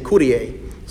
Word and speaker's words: Kyrie: We say that almost all the time in Mass when we use the Greek form of the Kyrie. That's what we call it, the Kyrie. Lord have mercy, Kyrie eleison. Oh, we probably should be saying Kyrie. So the real pronunciation.